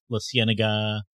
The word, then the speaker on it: La Cienega: Because California has tons of Spanish named things, but modern people, if you go to LA, no one pronounces it in the Spanish. I mean La Cienega.